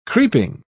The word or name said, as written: creeping